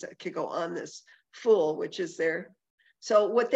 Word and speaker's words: Ful: And I asked the chef what it was, and it's called Ful, I think is how it's pronounced, F-U-L.